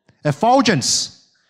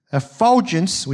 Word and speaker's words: effulgence: KJ told me how to say this word. How was it? Effulgence. Effulgence,